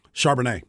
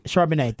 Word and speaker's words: Charbonnet: I always mispronounce his name. Is it Charbonnet? – Charbonnet. – Charbonnet. Thank you.